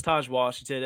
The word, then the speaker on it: Taj Washington: Is it Taj Washington? Am I saying that right? Am I just sounding like a fool? – No, it's Taj Washington.